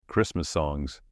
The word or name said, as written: Christmas songs